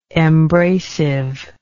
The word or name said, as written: embracively